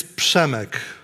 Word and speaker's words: Przemek: So my name is Przemek.